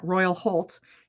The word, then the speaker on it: Royal Holt: Did I say that right? Yeah, Royal Holt.